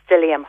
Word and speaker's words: psyllium: And then the fibre that you do want to put in is the psyllium husk.